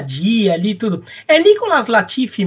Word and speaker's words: Latifi: Mas, mas essa Nicolas é, é, é, francês do Quebec, seria Nicolas a pronúncia é Nicolas Latifi mesmo.